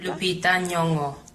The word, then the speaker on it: Lupita Nyong'o: Lupita Nyong'o. – Lupita Nyong'o. – Lupita Nyong'o. – Lupita Nyong'o. – Lupita Nyong'o. – Lupita Nyong'o. – Lupita Nyong'o. – Yeah, she's fucking awesome. – Lupita Nyong'o.